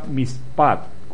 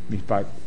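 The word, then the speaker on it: mishpat: mispat, con acento en la A, M-I-S-H-P-A-T, con acento en la A, mispat, para juicio, y sedek, o T-S-E-D-E-K, para justicia.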